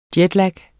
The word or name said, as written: jetlag